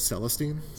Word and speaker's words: Celestine: How do you say it? – Celestine.